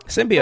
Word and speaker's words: symbiote: Symbiote. Like Simba, symbiote, yeah.